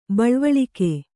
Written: ಬೆಳವಳಿಗೆ

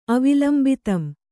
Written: ಅವಿಲಂಬಿತಂ

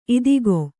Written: ಇದಕೋ

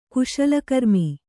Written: ಕುಶಲಕರ್ಮಿ

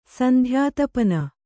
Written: ಸಂಧ್ಯಾತಪನ

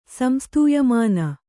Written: ಸಂಸ್ತೂಯಮಾನ

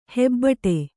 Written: ಹೆಬ್ಬಟೆ

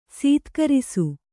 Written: ಸತ್ಕರಿಸು

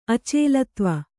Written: ಅಚಲತ್ವ

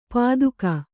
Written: ಪಾದುಕ

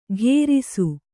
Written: ಘೇರಿಸು

